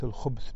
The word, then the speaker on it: الخبث: قال أي روايات الخبث بإسكان الباء. قال وقال النووي صرح جماعة من أهل المعرفة بأن الباء هنا ساكنة منهم أبو عبيد قال في النكت وقاله جماعة عامة أصحاب الحديث وهو غلط والصواب الخبث بضم الباء.